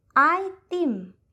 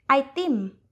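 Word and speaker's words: Aitim (ไอติม): Invece di avere ice cream, in thailandese diventa Aitim, Aitim, che è anche molto più veloce da pronunciare.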